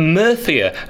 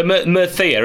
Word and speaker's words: Murcia: Now we've got Mr. Staveley joining us after a recent trip to Murthia, as they pronounce it. See, there's a delay there. You're interrupting me. Uh, that's it, though. Murthia, isn't it? That's how they pronounce it.